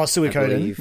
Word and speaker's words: Suikoden: Oh, Suikoden.